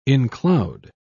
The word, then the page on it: encloud: British English